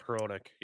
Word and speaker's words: Hronek: But if you're watching that Vancouver game and that goal by Hronek, I think that's how you say his name. – Yeah, Philip Hronek. Yep.